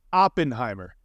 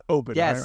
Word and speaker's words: Oppenheimer: It is Oppenheimer, not Oppenheimer. – Yes, thank you. I was like, I think he's pronouncing that wrong, and that's crazy when I know you're wrong. It's an op. There's an op there.